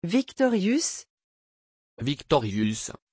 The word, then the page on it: viktorius: French